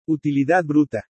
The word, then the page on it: UTILIDAD BRUTA: spanish